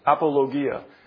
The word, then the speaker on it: apologia: Now, it comes from the word, I asked Earl before the service how to pronounce it because I usually put the emphasis on the wrong syllable. It's apologia.